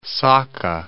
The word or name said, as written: Sakha